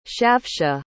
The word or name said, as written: shavsha